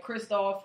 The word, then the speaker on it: Kristoff: Oh yeah, the, Christopher. Yeah, Kristoff um, St. John.